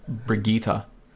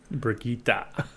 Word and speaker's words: Brigitte: I just think it's funny because everyone was pronouncing a name wrong for so long. So it's spelt B-I-G-E-T-T-E. And I was like, oh, Bigette, like baguette. No, it's the bigot. – Oh, yeah, it's like Brigitte. – Brigitte. – Like Vegeta. Like Vegeta, but Brigitte.